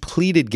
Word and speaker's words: pleaded: Li Wang, age 41, and Shen Mingbi, 60, each pleaded guilty. And you know what? That's the correct way to say it. Pleaded. It's not pled.